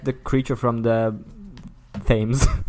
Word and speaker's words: Thames: Yes, I have to inform you, it's pronounced Thames. – It's the Thames. – Thames. – Thames is extremely good, though. Thank you. But what if, like... – I'm, like, trying... In German, we pronounce it Thames, but I was like, it's written so differently in English.